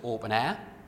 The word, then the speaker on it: OpenEHR: The standard, the open standard that the platform adheres to, is a standard called OpenEHR, often pronounced Open Air, which is a UK development, about 10 years mature, developed in UCL.